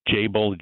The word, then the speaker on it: Jabil: It's Jabil.